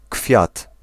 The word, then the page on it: blomma: /blʊma/